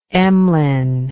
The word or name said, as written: Emlyn